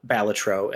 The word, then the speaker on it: Balatro: Is that what we call it? – That's how it's pronounced. That is, I had the chance to interview Local Funk, the developer of Balatro, and that is how he pronounced it.